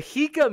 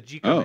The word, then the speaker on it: Jika: The Hika Man says, I'm disappointed in both James and Vosh. The J is pronounced like an H. Ever heard of a Spanish J?